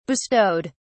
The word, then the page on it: bestowed: British English